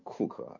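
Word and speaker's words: Coke: 反正库克啊，那个发音是库克，拼写是 Cook， Edward Cook 爵士，那是 16、17世纪最伟大的普通法的法官之一。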